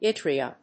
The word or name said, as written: yttria